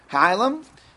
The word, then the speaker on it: hilum: If you take a look at the picture on page 100, better pronunciation, hilum.